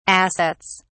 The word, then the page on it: assets: British English